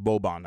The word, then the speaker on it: Boban: Boban.